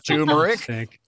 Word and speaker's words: turmeric: Turmeric. But anyway, so turmeric, there is an R in there and we don't have any silent letters in German. So we're going to go with that pronunciation.